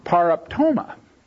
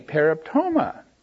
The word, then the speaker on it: paraptoma: But the word that we have here, paraptoma, P-A-R-A-P-T, long O-M-A, paraptoma, has the idea of a misstep and a stumbling and a fall.